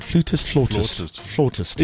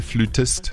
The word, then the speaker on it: flautist: Flautist. – In South Africa, we say flautist, but in the US, they say flutist.